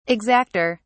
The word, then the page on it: exacter: British English